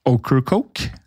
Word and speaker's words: Ocracoke: høsten 1718 så fikk eh, Spotswood nyss i at Blackbeard ofte ankra opp ved øya Okra-Coke, ved kysten av North Carolina. Hvordan ville du uttalt den øya? Okercoke?